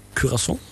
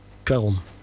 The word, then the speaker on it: Cuarón: Dus dan heb je het over George Clooney en Sandra Bullock en de regisseur Alfonso Cuarón. Nee, Cuarón.